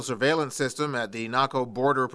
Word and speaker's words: Naco: Naco is about 80 miles southeast of Tucson. It also rhymes with taco.